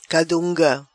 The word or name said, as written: Cadunga